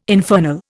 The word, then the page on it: infernal: Indian